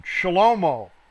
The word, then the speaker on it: Shlomo: Shlomo. Okay, that's how you pronounce it in Hebrew, not Solomon, Shlomo.